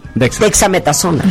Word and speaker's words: dexametasona: Imagínate que estén con un paciente y no sepan pronunciar desamectasona. – Dexa. Dexametasona.